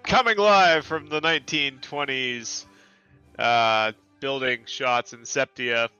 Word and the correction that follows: sepia: which apparently is pronounced Sepia. – Sepia. I just found out.